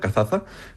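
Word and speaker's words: Cazaza: Bueno, seguimos con Kazaza. ¿Lo he pronunciado bien? – Sí, esta no es muy conocida, Kazaza.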